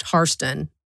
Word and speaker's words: Hairston: But one thing I noticed as you were reading is you pronounced the name Harston, and other folks pronounce it Hairston, correct? Can you explain just a little bit of the two pronunciations for us? – Sure. The Harston comes from the Irish immigrant. His name was Peter the Immigrant. He's the original family member that came over to America and started the family. – Right. – And it's pronounced Harston.